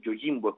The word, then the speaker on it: Yojimbo: Esto de Yojimbo es realmente en japonés es Yojimbo, es como es, a pesar de que se escribe con J.